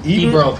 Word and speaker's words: Ebro: Well, you say his name is Ebron or Ebro? – Ebro.